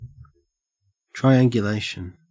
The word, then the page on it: triangulation: Australian English